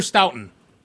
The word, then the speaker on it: Stoughton: Stoughton, that everyone was trying to figure out how to pronounce. – Stoughton or Stoughton? – Stoughton. – That's what I thought. – Stoughton. – Stoughton. – Yeah. – It depends on how close to Boston you are, right? – Yeah.